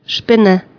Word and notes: Spinne: German

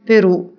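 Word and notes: broccia: Sardinian

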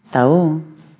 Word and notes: accrocher: French

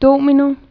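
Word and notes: apuchajj pujllay: Quechua

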